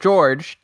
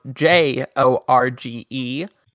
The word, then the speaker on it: Jorge: His mother, Erina Joestar, and his same age companion, Lisa Lisa, both pronounce it George, despite it being spelled J O R G E. But uh, since all the other inhabitants of La Palma are Spaniards, they all pronounce it Jorge.